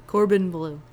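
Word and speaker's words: Corbin Bleu: How do you actually say his name? – Corbin Blue. – Corbin. Okay, because on the inner tube, the guy that does the show notes is named Corban, and it's spelled the same, but that's how he says it.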